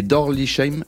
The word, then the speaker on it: Dorlisheim: Deux pâtissiers de renom associés dans deux boutiques en Alsace, à Mützig et Dorlisheim. Est-ce que je prononce correctement? – Oui, c'est ça, Dorlisheim.